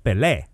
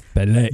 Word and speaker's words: Pelé: Pelé is pronounced Pelé. – Pelé, See the little accent above the E? – Yep. – That means that one's the strong one.